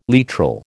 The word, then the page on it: literal: American English